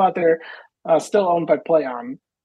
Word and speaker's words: PLAION: Sorry, it's called Play On, but it's spelled stupid. It's spelled PLAION. Obviously because they need to trade something, so it's called Play On, but it's spelled dumb.